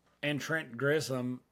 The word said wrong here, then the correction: Grisham: How do you say his name? Trent Grisham? – Grisham, yeah.